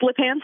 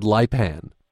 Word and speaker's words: Lipan: kind of just La Pan Street, short vowels. – I said Lipan.